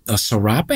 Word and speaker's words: serape: But she comes in like in a serape, is that how you pronounce it? – Yeah.